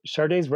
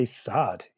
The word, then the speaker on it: Sade: You know, much like, you know, nobody knew the names of the people on Pavement, but Sade's record company actually had to put a thing on the cover of Diamond Life pronounced Sade, because, you know, if everybody would just say sod, you know, like, which was a very 1985 kind of reference to make.